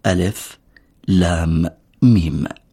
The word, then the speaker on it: الم: لا تكون كلمة ألم، إنما تلفظ كما هي ألف لام ميم.